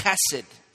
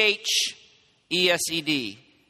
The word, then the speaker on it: chesed: it's it's chesed, something like that, right? C H E S E D is the way in the Hebrew it's it's pronunciated.